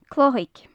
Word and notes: cloitheag: gaelic

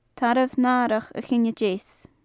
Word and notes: tarbh natharach a chinne deas: gaelic

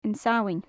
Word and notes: An Samhain: Gaelic

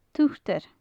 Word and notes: turtur: gaelic

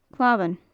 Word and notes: clamhan: gaelic

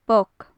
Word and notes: boc: gaelic

